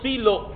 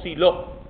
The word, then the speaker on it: Silo: Había un santuario en un lugar llamado Silo o Silo, se pronuncia de las dos maneras.